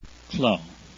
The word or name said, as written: clos